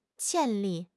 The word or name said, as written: 潜力